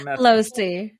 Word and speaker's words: Lohse: I'm Lohse. – Lohse, yeah. So the H is silent.